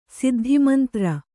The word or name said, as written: ಸಿದ್ಧಿಮಂತ್ರ